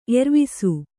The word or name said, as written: ಏರ್ವೆಸ